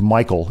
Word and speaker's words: Michiel: They had a guy named, I think his first name is pronounced Michael, even though it's spelled M-I-C-H-I-E-L.